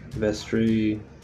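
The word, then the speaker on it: Vestri: Which are actually pronounced Istre, Vestre, Norstre, and Soothstre.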